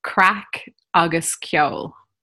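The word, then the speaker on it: craic agus ceoil: Crack August Kjol.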